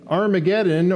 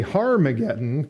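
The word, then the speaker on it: Harmagedon: But the fact of the matter is that Armageddon, or even more correctly, Harmageddon, kind of like our, at least the NASB mentions there in verse 16, that's the proper pronunciation, it has an H on the front of it there, is a real place that is in the nation of Israel.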